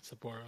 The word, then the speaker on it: Sapporo: So those wormholes will open up in Berlin, Seattle, and Sapporo, right? I said it right that time. – Sapporo, yeah. – Sapporo, okay.